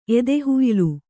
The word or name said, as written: ಎದೆಹುಯ್ಲು